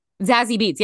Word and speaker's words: Zazie Beetz: Zazie Beetz. – Zazie Beetz.